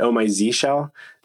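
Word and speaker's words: Oh My Zsh: I don't even know how to pronounce Oh My Shell, but I'll say Oh My Shell. – I pronounce it Oh My Z Shell.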